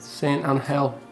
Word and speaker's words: San Angel: San Angel. It's a soft G.